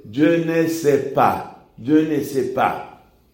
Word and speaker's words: pas: Y yo miro cómo está escrito y resulta que ese pa tiene P-A-S, pero la S no suena. Y la profesora me dice, no, la S no se pronuncia.